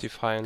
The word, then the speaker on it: Defiance: Ich meine, man hat ja schon gesehen, dass irgendwie mit Defiance, Defiance, wie spricht man das? – Defiance. Defiance.